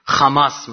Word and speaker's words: حماس: اولی هم سوتی زیاد میدادن، مثلا ه رو خ مینوشتن چون اینا تو تلفظ اشکنازی ها ه جیم رو خ تلفظ میکنن، خماس مثلا حماس رو اینطور میگن.